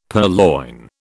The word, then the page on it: purloin: British English